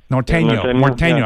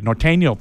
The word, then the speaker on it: Norteño: During the Tucson City of Gastronomy event that just took place, the Maize Festival, the gentleman that ran the Nortino Festival, am I saying that right? – Mm-hmm. – That type of music. Norteño, I got to say it right. Norteño Festival. He said he's talking to the mayor, and there's a good chance it's going to come back.